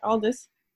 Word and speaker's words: Aldous: Yeah, Aldous.